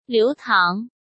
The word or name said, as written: Liu Tang